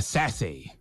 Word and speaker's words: Sasse: Donkey of the Day for Wednesday, May 20th, goes to Senator Ben Sasse. Or Sassy. I like Sassy. No accent on the E, though.